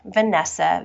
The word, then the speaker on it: Vanessa: My handle is, make sure I say it right, at Vanessa, V-A-N-E-S-S-A, underscore oil releaser.